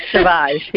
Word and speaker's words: Sauvage: We are speaking, and we're talking about a very powerful book that Dr. Sauvage, did I say that right? – Sauvage, yes. – Sauvage, I'm not French.